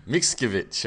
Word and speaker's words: Mickiewicz: Mickiewicz.